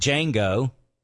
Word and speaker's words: Django: Throughout their travels, Dr. King Schultz learns that Django, the D is silent, hillbilly, is a married man.